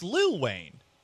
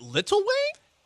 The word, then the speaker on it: Lil Wayne: Wait, it's Lil Wayne. Is it Little Wayne? – No, that's how you say it.